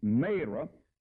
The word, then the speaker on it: Marah: When they came to Marah, or Marah, some people call it Marah, but my Bible said it's a long A, Marah, they could not drink of the waters of Marah, for they were bitter.